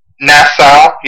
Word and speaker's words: Nassau: Is that how you say it? Nassau? – Nassau, yeah.